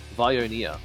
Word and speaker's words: Viognier: It's actually pronounced as a spirited Viognier, is how they say it.